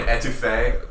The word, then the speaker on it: étouffée: A fucking étouffée.